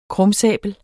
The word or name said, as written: krumsabel